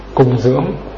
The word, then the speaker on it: cúng dường: Cúng dường tức là cũng là tiếng âm thứ Phán, tức là thứ Hán là cung dưỡng. Cúng dường hay là các người đọc là cung dưỡng.